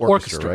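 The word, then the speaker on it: Orkustra: Does it pronounce it Orchestra, right? – Yeah. – It's spelled like O-R-K.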